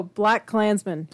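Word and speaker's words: BlacKkKlansman: Black Man? – Black Klansman. – Oh, Black Klansman.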